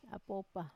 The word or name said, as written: apopa